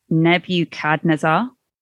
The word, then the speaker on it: Nebuchadnezzar: Uh, the king, whose name I said very wrong, I don't even know how I said it, is Nebuchadnezzar.